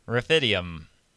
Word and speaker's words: Rephidim: Rephidim.